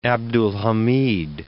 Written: Abdulhamad II

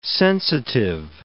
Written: sensitive

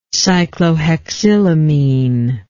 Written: cyclohexylamine

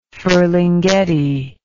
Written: Ferlinghetti

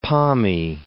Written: Pommie

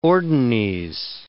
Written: ordines

